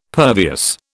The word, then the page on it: pervious: British English